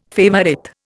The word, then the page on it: favourite: Indian